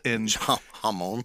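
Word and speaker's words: jamón: We went to Spain, and that's how they pronounce it. I'm not trying to be a jerk. – No, I know. – It's jamon. That's how they pronounce this.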